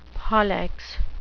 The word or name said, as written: pollex